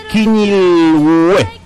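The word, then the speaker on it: Quiñilhue: Denominada, me dicen, por el pueblo mapuche como, perdón, sí, lo digo mal, Quiñilgüe, ese es el nombre, que la denominación, o así le dice la mayor parte del pueblo mapuche. Quiñilgüe, así se llama.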